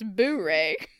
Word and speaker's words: Boer: I think it's boar. – Boar. Yeah. Okay. Dan says, I met a white South African girl in college. Why she gotta be white, Dan? Come on. Very cute. She didn't want to talk to me, but I was determined. I was out of topic, so I started a convo about the Boer Wars. She said, it's pronounced Boo-ray, and looked disgusted.